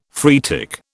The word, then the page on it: phreatic: British English